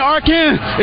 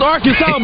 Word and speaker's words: Arkansas: I got that wood, and that's how you pronounce it, ain't Arkansas. It's Arkansas, baby.